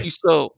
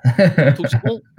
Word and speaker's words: Tussaud: Yeah, Tussaud. That's how you pronounce it, right? – Yes. – Tussaud.